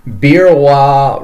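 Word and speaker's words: Beerwah: So yeah, they moved to Queensland to open up the Birrawa Reptile and Fauna Park in 1970.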